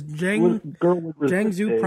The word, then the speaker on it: Jiangsu: It's in China's Jiangsu province.